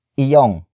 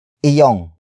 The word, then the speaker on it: iyong: But before that, we have iyong. Iyong. Iyong. Iyong is spelled as I-Y-O-N-G.